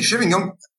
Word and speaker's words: Chevignon: Das klingt bei mir immer mit meiner feuchten Aussprache nach Champignon oder sowas. Nee, Chevignon, wirklich, finde ich bis heute eine ehrenwerte Marke.